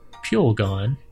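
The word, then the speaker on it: pulegone: The data indicating that exposure to the FDA-regulated known carcinogen mint oil extract, Pulgon, rhymes with mule gun,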